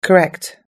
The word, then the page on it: correct: American English